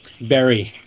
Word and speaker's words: Barre: I pronounce Barre right. It is Barre? – Barry. – Barry. Oh, God. Sorry.